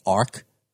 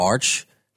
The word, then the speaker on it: Arch: And when I interviewed Richard, I accidentally mispronounced saying it Arc instead of Arch.